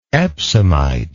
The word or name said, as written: epsomite